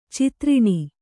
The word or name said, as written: ಚತುರಣ